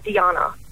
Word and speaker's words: Dijana: D-I-J-A-N-A. – D-I-J. – J. – Yeah, like Yana. It's pronounced like a Y.